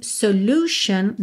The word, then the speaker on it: solution: Em solution, nós não temos U, tá? É U mesmo, solution.